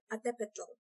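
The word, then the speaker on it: altepetl: All right, so the city-states that we're talking about are called Atepetl, and in a lot of Nahua words, you'll see the TL and the L is silent.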